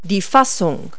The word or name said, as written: socket